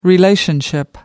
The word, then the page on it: relationship: American English